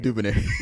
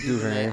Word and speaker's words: DuVernay: DuVernay. – DuVernay. I'm so DuVernay. – If you ever hear, listen. I'm sorry. I try. Ava DuVernay. Got it. Woo. – We love Ava DuVernay.